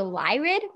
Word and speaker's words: Lyrid: Basically, I think the most recent, or the upcoming one is the meteor shower, the Lyrid meteor shower, if I'm not mistakenly pronouncing it. – No, you're doing perfect. That's exactly how you say it.